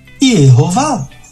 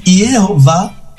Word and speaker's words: Yehová: Então, alguém pegou, um grupo pegou depois as vogais do nome Adonai, colocou no tetragrama sagrado e surgiu a pronúncia Yehová. De modo que Yehová é um nome híbrido. Ele é uma composição das consoantes do nome de Deus com as vogais da palavra Adonai, que significa meu Senhor.